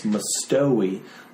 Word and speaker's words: Mustowy: Mine is always, whenever people ask me how to say my name, I'm like, it's Mustowy. Like, oh, that's how you say it. Not ow. It doesn't hurt you.